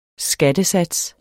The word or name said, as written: skattesats